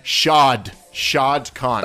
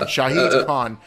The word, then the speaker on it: Shahid Khan: Shao Kahn? – Not Shere Khan. No. Shad Khan. Shahid Khan. But his friends call him Shad.